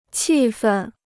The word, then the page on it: 气愤: qì fèn